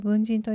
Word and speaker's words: 分人: おそらくなんですが、シーズン 1 ですね、このポッドキャストがまだの今日も待ってたようだった時にですね、あの最初の頃に分人、分ける人と書いて分人と読むんですが、この概念、考え方について取り上げたことがあると思います。聞いてくださったという方もいらっしゃるとは思うんですが、少し今日説明させていただくと、この分人